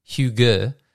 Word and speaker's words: hygge: Pronounced hygge, the word is said to have no direct translation in English, though cozy comes close.